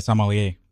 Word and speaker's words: sommelier: I said sommelier. – Oh, that was right. That was right there. You got it right. Yeah. All right. Last night you were saying it weird. – Oh, I said sommelier anyway.